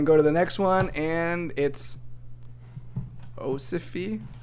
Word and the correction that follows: ossify: Okay, I pronounced it completely wrong. Jeez. It's ossify.